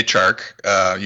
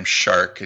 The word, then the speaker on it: Chark: Well, yeah, DJ Chark. You made fun of me last year. I think I pronounced his name Shark, and you had the Jaws theme going on in the background.